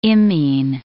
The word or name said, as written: imine